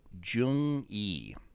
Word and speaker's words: Jung_E: It was on Netflix, because I watched Flick Connection, and Darren Van Dam suggested underappreciated sci-fi movies, and Netflix, and I watched Jung Yi. It's spelled J-U-N-G underscore E, the letter E.